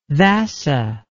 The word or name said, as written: vassa